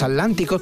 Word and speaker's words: Aslánticos: En la radio voy a exagerar la pronunciación para que, porque si la gente escucha los Aslánticos, pues piensa que es como el, bueno, no, como el océano, ¿no? Atlántico, vale.